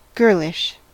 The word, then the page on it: girlish: American English